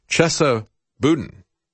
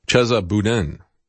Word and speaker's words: Boudin: Chesa Boudin, I think is the way he pronounced his name, Chesa Boudin. B-O-U-D-I-N.